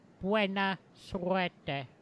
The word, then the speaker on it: buena suerte: How'd that pronunciation? Buena suerte. – Oh, una lengua bendiga, which means you've got a blessed tongue.